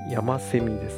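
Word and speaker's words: 山翡翠: 山セミ。 – あ、こういう字を書いて山セミって読むんだ。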